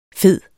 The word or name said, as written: fede